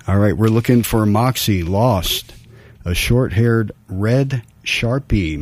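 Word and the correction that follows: Shar-Pei: mix. Sharpay, excuse my pronunciation there.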